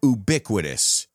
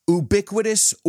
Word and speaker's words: ubiquitous: Ubiquitous or ubiquitous? I've heard it pronounced both ways by very intelligent people. So I guess that's up to you. Either, either. Neither, neither. Hey, these are words that have two pronunciations that are accepted everywhere.